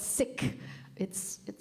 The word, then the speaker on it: Sikhi: Actually, it's called Sikh. It's an awkward syllable to say in English because it sounds like sick, but Sikhi.